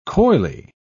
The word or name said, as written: coyly